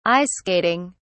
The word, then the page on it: ice skating: British English